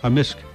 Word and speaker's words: amisk: Amisk. – Amisk. – Amisk. – So the K can be silent, but in generally it's...